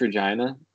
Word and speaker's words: Regina: Did you know there's a town in Canada called Regina? – I did not know that. – It's pronounced Regina, but so when I lived there for a little while, worked there.